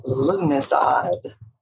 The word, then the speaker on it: Lughnasadh: It's pronounced Lughnasadh, or Lammas for short. – Oh, my goodness gracious. But if you saw how it was spelled, it was like, it's like trying to pronounce a city in Massachusetts. It just, there's letters there that aren't being used. It's an interesting one for sure. – You know why? It's a Celtic word, and the Gaelic. So the Gaelic, there's letters that are pronounced differently.